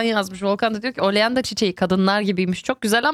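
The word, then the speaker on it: Oleander: Gonca yazmıştı ki oleanda diye okunuyor dedi.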